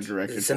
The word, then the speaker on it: Zemeckis: It also stars Helen Hunt, and I really like the director, Robert Zemeckis, if that's how you pronounce it. Also, he wrote and directed Zemeckis.